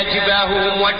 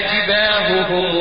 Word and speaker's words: جباههم: يقول لك يعني لما تيجي عندك هائين او هاء بنت بين همزه او ما شاب او هاء بعدها الف، صف يعني ميز بين نطقه، يوم يحمى عليها في نار جهنم فتكوى بها جباههم. ما تجيش تقول لي فتكوى بها جباههم لا، انا عاوز نطق كل هاء لوحديه.